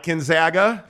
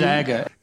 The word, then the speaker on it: Gonzaga: So way back in the day, we were saying Gonzaga. – Gonzaga. – We Google searched proper pronunciation of Gonzaga.